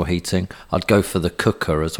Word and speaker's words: Quooker: It's the cooker. Spelt quooker, Q-U-O-O-K-E-R, but pronounced cooker.